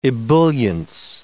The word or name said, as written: ebullience